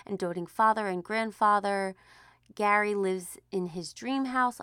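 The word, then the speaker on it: Gerry: So, every Monday, we're going to be finding Jerry, but it sounds like, oh no, guys, it's pronounced like Gary, not Jerry.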